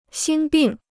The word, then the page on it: 心病: xīn bìng